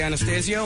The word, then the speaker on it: Anastasio: In our studio, we have, ladies and gentlemen, Mr. Trey Anastasio here. And then Casey was giving me a hard time about the pronunciation of his name.